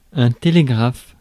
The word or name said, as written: télégraphe